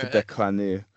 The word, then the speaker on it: Tippecanoe: Up on the Tippecanoe. – Is that how you pronounce it? – That's the French pronunciation, yes. That's how Tecumseh pronounced it. – Right. – Tippecanoe.